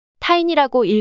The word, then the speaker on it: Tiny: 이 미티어 디벨롭먼트 그룹이 찾은 새로운 스폰서는 티니, T-I-N-Y, 티니라는 회사였습니다. – 타이니라고 읽어야 하는데 티니라고 읽었습니다. 타이니로 수정합니다.